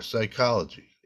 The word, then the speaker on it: psychology: Pterodactyl or psychology. It's, uh, wait, psychology doesn't have a T. – It has a P. It is a silent P.